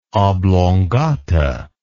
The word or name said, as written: oblongata